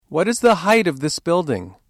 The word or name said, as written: height